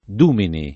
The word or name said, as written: Dumini